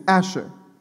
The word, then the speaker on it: asher: In the first two verses, the word is "asher." Right? The transliteration pronunciation is "asher," which is often translated "happy."